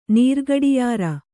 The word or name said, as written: ನೀರ್ಗಡಿಯಾರ